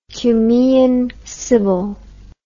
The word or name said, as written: cumaean sibyl